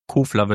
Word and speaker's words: Kofler: Kofler, das ist mal, da komme ich immer durcheinander. Kofler wird da ausgesprochen. – Ja, österreichisch.